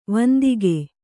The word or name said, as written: ವಂದಕ